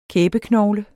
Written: kæbeknogle

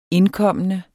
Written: indkommende

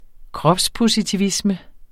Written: kropspositivisme

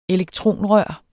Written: elektronrør

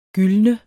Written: gyldne

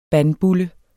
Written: bandbulle

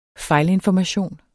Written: fejlinformation

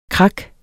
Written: krak

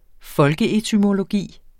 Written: folkeetymologi

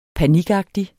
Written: panikagtig

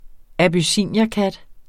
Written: abyssinierkat

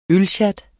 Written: ølsjat

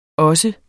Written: også

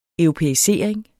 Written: europæisering

